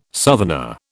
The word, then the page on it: southerner: British English